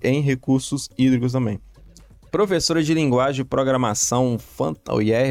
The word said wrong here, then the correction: Python: eu acertei a pronúncia? É isso mesmo? – Python. – Python, é. desculpa.